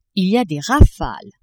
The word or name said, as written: il y a des rafales